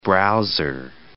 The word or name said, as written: browser